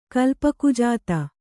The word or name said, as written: ಕಲ್ಪಕುಜಾತ